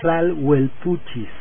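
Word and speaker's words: tlahuelpuchis: ya me regañaron, por cierto, ya me regañó el antropólogo, que se pronuncian Tlalhuelpuchis. Es la pronunciación correcta, las Tlalhuelpuchis.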